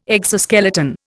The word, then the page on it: exoskeleton: Indian